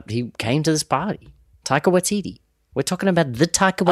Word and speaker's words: Taika Waititi: That is like Tiger, but with a K, and then Waititi is like Waikiki. – Taika Waititi.